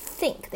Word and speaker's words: think: 今天呢，咱们还是 360 度的去学习一下 think 的一个发音。 Think 呢，其实最麻烦的一个发音呢，就是这个 th 啊，经常呢有些同学就发成了 think 啊，其实错误的。那 th 这边呢，它发的是一个 th 的一个发音，那舌尖呢轻轻的啊，稍微出来一点，然后呢牙齿呢轻轻的去咬合你的舌尖，